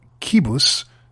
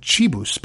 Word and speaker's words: Qibus: He is the founder and CEO of a company called Kibus, except that it isn't, because I've learned it is not pronounced Kibus. Rather, it's pronounced Chibus, because you see, it's spelled Q-I-B-U-S, and the Q-I is the Qi, as in the Chinese word for energy, if I'm not mistaken.